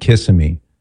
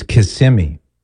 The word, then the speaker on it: Kissimmee: I will never go back to my wayward days of saying Kissimmee. It's Kissimmee. Or I've been adamantly corrected on that. So Kissimmee, Florida, this is Dennis.